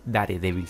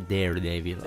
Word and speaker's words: Daredevil: Si vuelve de Punisher, si vuelve de Daredevil, eh, tuve una compañera que dice, no se dice Daredevil, se dice Daredevil.